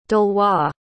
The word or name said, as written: delois